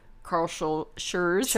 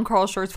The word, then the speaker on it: Schurz: We went to Carl Schurz. It's hard to pronounce that. – We lived on Carl Schurz Park for like 6 years. – Yeah, I know. – Schurz.